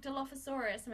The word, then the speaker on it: Dilophosaurus: But they have the like Dilophosaurus.